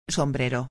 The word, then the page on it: sombrero: espanhol